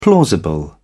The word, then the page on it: plausible: British English